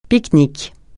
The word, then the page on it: pique-nique: francais